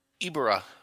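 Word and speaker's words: Ebirah: God Ebra or Ibra? How do you say it? – Ibra, Whore of the Deep.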